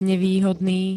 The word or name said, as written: nevýhodný